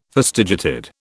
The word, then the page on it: fastigiated: British English